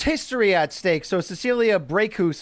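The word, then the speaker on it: Brækhus: Very quickly, I never know how to pronounce her last name, the Norwegian who looks like she has the, and I know you're going to make fun of this word, but I believe this is what it's called, a diphthong, which is when the A and the E are connected. Cecilia Breakhouse, she has a big fight coming up on DAZN.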